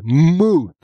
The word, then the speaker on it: Muth: They made a big deal out of pronouncing it that way. Muth.